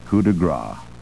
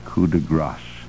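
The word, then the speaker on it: coup de grâce: The French words, expression coup de grace, a finishing stroke, is often, I would say more often than not, pronounced coup de grace, and it cannot be coup de grace. G-R-A-S is gras, G-R-A-C-E is grasse, and this is coup de grace.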